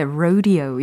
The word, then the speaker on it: rodeo: 그러고 또 로데오라고 우리가 알고 있었는데 rodeo 이렇게 발음이 된다는 어, 것도.